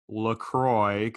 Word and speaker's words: LaCroix: Okay, so in an article, LaCroix confirmed it's pronounced LaCroix, and it mentions also that it rhymes with enjoy.